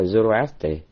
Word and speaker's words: Zoroastre: Tiếng Đức thì nó đọc là Zarathustra, còn tiếng Pháp nó là Zoroastre. Rồi còn bên tụi Đức nó đọc theo tiếng tiếng Ba Tư là Zarathustra, mục tiêu là Zarathustra.